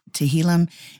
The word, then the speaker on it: Tehillim: the book of Psalms is titled the Tehillim. Is that how you pronounce it? – Close enough. – Tehillim in Hebrew, which means songs of praise.